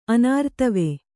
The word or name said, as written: ಅನಾರ್ತವೆ